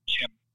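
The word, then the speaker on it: Qin: I'm pretty sure it's just pronounced Kin.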